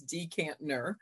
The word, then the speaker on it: Dikembe: It is a common mistake. It really is. It's like there is a second N there. It's Dikembe.